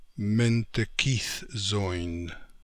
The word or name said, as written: Mentekithzoin